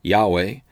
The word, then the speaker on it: Yahweh: Now Yehovah is the Hebrew name of the Lord, and most often Yehovah is translated in our English Bibles with all capital L-O-R-D. The Hebrew letters are Yod, He, Vav, He. Sometimes this is pronounced Yahweh, other times Yehovah.